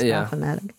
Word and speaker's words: Baphomet: I think Baphomet sounds so much nicer, but it's Baphomet.